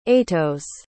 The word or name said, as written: aetos